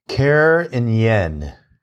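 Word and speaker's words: Carignan: Karen Yen is how Wine Folly says you pronounce this.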